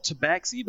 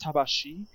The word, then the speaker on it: Tabaxi: Did you know that the term tail boy, tabashi, which a lot of people call tabaxi, but it's actually pronounced tabashi, is known to be a bit of a slur to them.